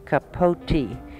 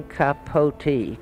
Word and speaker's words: Capote: Well, before I answer that question, I think I should explain that my name is really pronounced Capote. Everyone in Munich. Everyone calls me Capote. It's really Truman Capote. Capote.